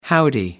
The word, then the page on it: howdy: American English